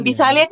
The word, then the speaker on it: Visalia: Hay pelea el 13 de mayo en Pisalia, California. No sé cómo se pronuncia. Pisalia. Bueno, en Visalia, California, este, voy contra una chica que es zurda y pues va a ser, nunca he peleado en ese peso tan pesada.